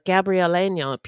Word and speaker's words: Gabrieleño: Los Angeles sits on the coast of Southern California and was the ancestral home of the Gabrieleño people, the Chumash and the Tadaviam, or Fernandeños. – I'm so proud of you for pronouncing the Ñe letter.